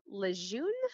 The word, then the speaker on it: Lejeune: Um, there was a case of water contamination, particularly TCE, um, and this was present at Camp Lejeune, I think I'm pronouncing it correctly, that's, that's um, right.